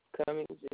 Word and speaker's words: Cummings: Yes, Tarina Cummings is spelled T-A-R-E-I-N-A, last name C-U-M-M-I-N-G-S.